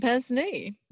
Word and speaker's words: pince-nez: She chose the golden pince-nez. – Yeah, that's the one. That's how you say it.